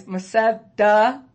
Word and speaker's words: Bethesda: I'm saying it wrong. Sorry, guys. Bethesda. Okay.